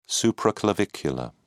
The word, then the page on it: supraclavicular: British English